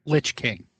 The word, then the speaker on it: Lich: Is it pronounced Lich King or Leish King? – Lich King.